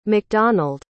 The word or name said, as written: macdonald